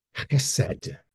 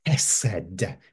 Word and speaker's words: chesed: It is chesed. Chesed. It starts with an H, but actually it's pronounced sort of gutturally from the back of your throat, as though you're clearing your throat.